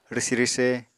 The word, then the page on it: rwsi-rwse: Apatani